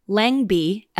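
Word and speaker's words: Lengby: It's spelled Langby, L-E-N, but it's pronounced Langby in Minnesota.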